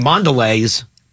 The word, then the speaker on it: Mondelez: I mean, Mondelez. Am I saying it right? Mondelez? – It's Lee's. – Mondelez? Oh.